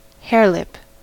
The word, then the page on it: harelip: American English